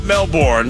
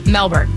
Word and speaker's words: Melbourne: It's not Melbourne, it's Melbourne. Or is it Melbourne? – Melbourne. Or Melbourne. Which, Melbourne. Apparently they don't pronounce the R.